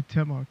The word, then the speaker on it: Temoc: So you can just call me Timok. It's a lot easier than trying to say my full name.